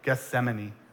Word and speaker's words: Gethsemane: When you sang it, I realized I've been saying that wrong so many times. I had enemy, Gethsemane. There we go. Had to say it slowly.